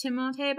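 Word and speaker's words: Timothée: Excuse me, Austin. It's Timotei. – Please tell me that's not true. – No, it's true. I saw an interview with him. He's like, yeah, my name's actually pronounced Timotei, but I don't correct people because it happens so much.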